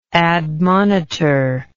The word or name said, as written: admonitorial